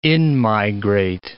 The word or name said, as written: in-migrate